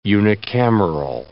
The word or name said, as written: unicameral